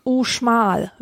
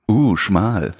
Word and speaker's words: Uxmal: Also Mexiko, so eine Ruinenstätte Uxmal. – Uxmal. – Uxmal wird's ausgesprochen. – Uxmal.